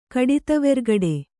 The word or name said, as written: ಕಡಿತವೆರ್ಗಡೆ